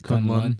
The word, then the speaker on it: Kunlun: So there's a fungus coming, it's in the subways, it comes from, what's Iron Fist's, how do you say it? – Kunlun.